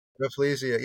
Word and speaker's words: Rafflesia: I don't think this was a softball question at all, but it was a Rafflesia. Is that how you say it? – Rafflesia. Yeah, named after Raffles, the British explorer and the first guy that took over or established Singapore.